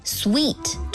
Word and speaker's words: suite: 如果是多人旅行或者是全家出游，你还可以考虑订上一间套房 suite，注意发音是 suite， s u i t e， suite。